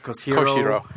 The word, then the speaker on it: Koshiro: You totally butchered that name. – Yes, so, he did. – Who cares? – That was horrible. – Koshiro. Yuzo Koshiro.